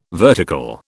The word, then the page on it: vertical: British English